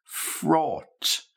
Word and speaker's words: fraught: Okay, third word, fraught. Fraught. Now think of the word taught. Teach, taught. And now fraught.